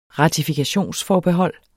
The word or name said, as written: ratifikationsforbehold